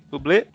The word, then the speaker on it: Bublé: Bublé.